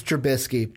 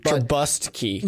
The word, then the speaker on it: Trubisky: We know it's Trubisky. – Trubisky.